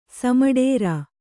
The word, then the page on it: ಸಮಡೇರಾ: kannada